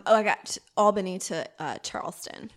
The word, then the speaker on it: Albany: How do you say it? – Albany. – Oh, Albany. – That's how everyone says it. Albany, Georgia.